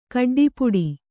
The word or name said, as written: ಕಡ್ಡಿಪುಡಿ